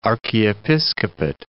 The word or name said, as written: archiepiscopate